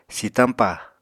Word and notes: sitañpa: Apatani

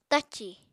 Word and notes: tacì: Apatani

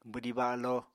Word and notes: bwdí balo: apatani

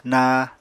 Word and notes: naa: Apatani

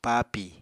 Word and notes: paapi: Apatani

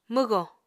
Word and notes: mwgò: Apatani

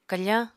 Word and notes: kafáñ: apatani

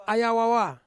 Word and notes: aya wawa: Ansus

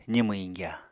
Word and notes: zimw iñgya: Apatani

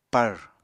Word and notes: pàr: Apatani

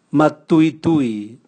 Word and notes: matuitui: Raga

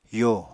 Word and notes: yo: apatani

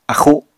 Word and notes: axu: apatani

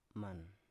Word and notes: man: Biak

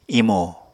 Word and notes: imo: apatani

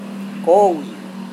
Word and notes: kowi: Serewen

